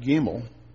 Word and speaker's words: Gimel: That little character that you see in your Bible right above verse 17 is pronounced Gimel.